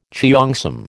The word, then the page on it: cheongsam: American English